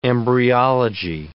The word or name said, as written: embryology